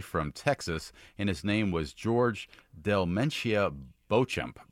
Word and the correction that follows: Beauchamp: Oh, well, actually, it's pronounced Beecham, which is weird. – Beecham. Okay. – Yeah, because it's spelled like the French Beauchamp, so you think.